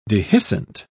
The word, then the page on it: dehiscent: British English